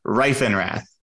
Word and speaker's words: Reifenrath: Marc Reifenrath.